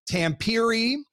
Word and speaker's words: Tampere: And, uh, you know, I, I, got some help, especially on Roope Hintz's city, which is spelled T A M P E R E, which could be Tampere, Tampere. Yep. But uh, one of uh, of uh, Roope's family members came up to me and he goes, do you want to say it correctly? I said, Of course I do. He said, All right, well, you need to say Tampere.